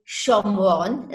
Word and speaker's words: Shomron: He sells it to Omri, and they say that Omri named the city Shomron, that's the Hebrew pronunciation, in Shomron, after the man Shemer that he'd bought the hill from.